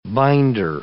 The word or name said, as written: binder